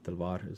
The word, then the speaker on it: Talwar: Talwar is the way to pronounce it, sort of more in Hindi or Urdu.